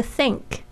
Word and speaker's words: think: If 就是如果， If you think 就是说你，如果你认为这个 think 也可以当认为想法，大家特别注意一下这个 th 的发音，是这个牙齿就是轻轻的咬住舌尖发出的这个 th 的气音。